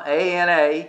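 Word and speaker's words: ana-to-le (ἀνατολή): It's ana, A-N-A-T-O-L-E, and it's a long E, ana-to-le.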